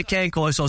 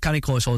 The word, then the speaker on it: Cane Corso: Yeah, people say cane corso, some people say canny corso. – Everybody's got a different version, fine. – But canny corso is the right way to say it.